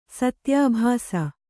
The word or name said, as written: ಸತ್ಯಾಭಾಸ